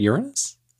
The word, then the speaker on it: Uranus: I do remember Bill Nye, the science guy, deliberately making sure to pronounce it Uranus.